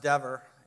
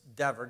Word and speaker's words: Dever: Well, Mark Dever, and his name is pronounced Dever. It's spelled Dever, but it's Dever, D-E-V-E-R, shared an excerpt from a biography of Langston Hughes, the poet of the Harlem Renaissance, published his autobiography.